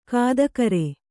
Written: ಗೊತ್ತುಗುರಿ

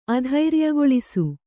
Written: ಅಧೈರ್ಯಗೊಳಿಸು

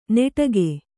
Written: ನೆಟಗೆ

